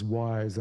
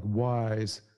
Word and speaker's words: Wwise: One is Wwise and it's spelled W W I S E.